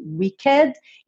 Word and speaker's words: wicked: Uma palavra que termina em ed e o é é pronunciado. Mas por quê? Isso aqui não é um verbo no passado, tá bom? É o adjetivo wicked e se pronuncia. Pode caprichar no é wicked, wicked, tá?